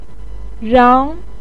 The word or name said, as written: rang2